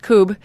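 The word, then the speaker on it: Kubb: If you looked at it phonetically, it would look like cub. – Right. – It's K-U-B-B, but it is pronounced Coob.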